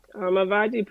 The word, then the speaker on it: Avodgy: Avodgy. – When you think, just think about Versace, and then you got Avodgy. – Okay, what's the name? What's the meaning behind the name? – Avodgy pretty much stands for our slogan, Style is Universal.